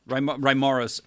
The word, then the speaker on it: Reimarus: Who was Herman Samuel, and am I pronouncing his last name right? Reimarus? – Reimarus.